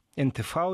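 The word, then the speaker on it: n-tv: Он дал расширенное интервью НТВ, НТВ, если правильно говорить по-немецки.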